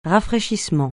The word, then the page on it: rafraîchissement: francais